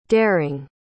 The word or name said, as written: derring